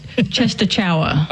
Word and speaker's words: Częstochowa: It looks like Częstochowa.